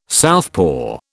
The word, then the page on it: southpaw: British English